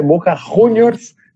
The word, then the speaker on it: Boca Juniors: A pronúncia correta é Boca Juniors,